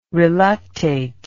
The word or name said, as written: reluctate